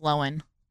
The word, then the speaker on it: Lohan: She came out not too long ago and said, yeah, you guys have been saying my name wrong. It's Lohan.